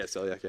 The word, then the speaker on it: celiac: Timothy Wisenhunt says, and I hope I'm pronouncing this correctly, I have celiac, is that correct? – Yeah, celiac, yeah.